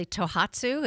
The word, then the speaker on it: Tohatsu: I hope I pronounce this correctly, Tohatsu. Is that correct? Mm-hmm. How does Tohatsu compare to Yamaha and Mercury? – Tohatsu is a Japanese company that's been around a long time ago. Nissan and Tohatsu was about the same thing.